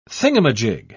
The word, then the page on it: thingumajig: British English